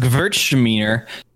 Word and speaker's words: Gewürztraminer: It's pronounced Gewürztraminer. That rhymes with Hertzmawiener.